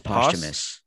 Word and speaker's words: posthumous: No, I don't blame you. I pronounced it like that a long time, and then my mom corrected me. – What is it? – It's posthumous. – Posthumous. – Posthumous. – Like after death?